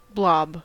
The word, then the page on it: blob: American English